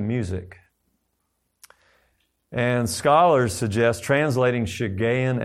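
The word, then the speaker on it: Shiggaion: is that it refers to itself as a S-H-I-G-G-A-I-O-N. It's pronounced Shigayon.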